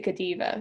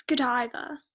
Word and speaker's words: Godiva: I always pronounce it Godiva because I'm uneducated. – Mm-hmm. – Godiva. – A common mistake. – Common mistake. – But yes, it's Godiva.